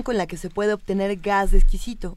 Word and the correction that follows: esquisto: Este, no lo dije bien. – esquisto. Esquisto, porque eh.